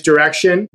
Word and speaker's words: Direxion: The X, it's not pronounced like a French baguette or something, but it is direction.